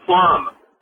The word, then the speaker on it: plumb: Because I wanted to talk about the word plum. – Plum. – It was brought up the other day in your spelling bee thing. – Yeah. – So P-L-U-M-B with a silent B at the end.